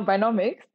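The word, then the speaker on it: Buynomics: Ja, genau, Binomics. Ähm, geschrieben bei, also wie, wie kaufen.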